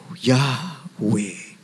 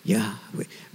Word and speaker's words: Yahweh: But the word is J-W-H-W. And it is the breathing in and the breathing out. That's how it is pronounced. Yahweh. Yahweh.